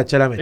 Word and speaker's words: Chalamet: Chalamet, algo así se pronuncia. – Pero sí, muy famoso. – Es muy famoso. Es como el actor de. – Chalamet. – Ahí está, Chalamet.